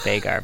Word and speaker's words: Veigar: It's pronounced like vine. Veigar, Veigar joke.